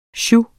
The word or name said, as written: SIOUXEN